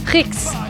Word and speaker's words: Rixe: It's not French, it's Spanish. – But they're from France. – Oh, Rix.